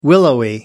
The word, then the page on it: willowy: American English